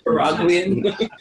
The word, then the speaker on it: Nicaraguan: Is that how you would say it? – Nicaraguan. – Nicaraguan. I love it. Nicaragüense. If you want to try it, it would be how you say it in Spanish. But Nicaraguan is perfect.